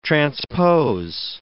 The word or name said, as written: transpose